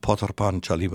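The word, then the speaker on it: Pather Panchali: Wird Potar Panchali geschrieben. Potar Panchali wird es ausgesprochen, das ist Bengali.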